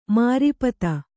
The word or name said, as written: ಮಾರೀಪತ